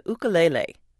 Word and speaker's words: ukulele: Lyle Ritz's instrument of choice is the ukulele. And yes, that is the correct Hawaiian pronunciation.